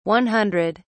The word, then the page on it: 100: British English